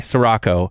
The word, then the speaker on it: Sirocco: I'm reading your latest novel, which is Sirocco. Am I saying that right, Dana? Sirocco? – Yes. – Okay, Sirocco.